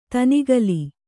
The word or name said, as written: ದನುಕುಲ